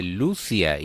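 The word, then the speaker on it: Luzia: Sí, realmente ella dice Lucia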